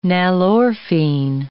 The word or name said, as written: nalorphine